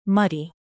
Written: muddy

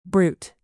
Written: brute

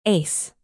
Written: ace

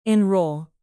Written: enroll

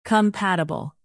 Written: compatible

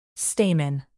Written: stamen

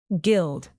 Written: gild

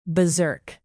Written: beserk

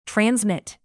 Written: transmit

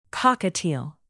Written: cockatiel

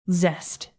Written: zest

